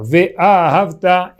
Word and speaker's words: ve'ahavta: O certo, a pronúncia certa é que essa palavra é oxítona. Ve'ahavta é um imperativo de amar Hashem.